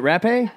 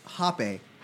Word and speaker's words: rapé: Is it rapé? – It's pronounced hoppe. – Hoppe. Oh, of course.